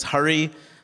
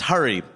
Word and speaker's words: Hari: And introductions are weird for me because my name is Hari, so people mispronounce it all the time. So this guy's like, hey, man, what's your name? I'm like, it's Hari. He's like, Hari? No, it's Hari. He's like, Hari? No, it's Hari. Harry?